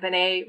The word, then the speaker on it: Benet: And the name itself, I thought it was interesting that Benet rhymes with O'Day.